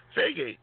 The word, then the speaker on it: Feige: Feige, whatever his last, however you say his last name, has previously said it right.